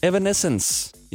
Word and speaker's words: Evanescence: Men min kollega Ida Sofia, hende der sender i dag faktisk fra 10 til 14 i Indigo, hun har skrevet øh, Evanescence. Jeg udtaler det helt sikkert forkert. Hvordan pokker siger man det navn? Evanescence. Yes.